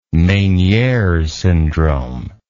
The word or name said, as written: menieres syndrome